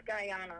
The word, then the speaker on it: Guyana: There was nothing where they were in, I'm going to pronounce this country wrong, but Guyana, I think that's how it's pronounced in South America. – I believe it's Guyana.